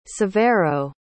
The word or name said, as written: severo